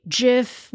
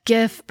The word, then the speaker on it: GIF: GIF, because it's graphic. So the dictionaries that I looked at say both pronunciations are correct. So how's that? It's debatable, but as it turns out, both are correct.